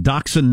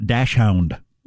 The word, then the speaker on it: Dachshund: Please tell Marshall that it's pronounced Dachshund, not Dashhound.